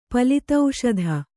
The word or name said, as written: ಪಲಿತೌಷಧ